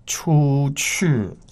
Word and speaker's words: 出去: 出去. So get your tones right. The first and fourth tone. – 出去. – Yes. – Go out. Again, please. – 出去.